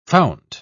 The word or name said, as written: fount